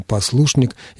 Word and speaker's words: послушник: Сегодня почти невозможно услышать традиционное правильное ударение на первом слоге. В слове «послушник» всего 3 слога, но даже в 3-сложном слове ударение переходит на 2-й. Сейчас говорят практически исключительно послушник, я уж не говорю о послушничестве и тому подобных образованиях от этого слова.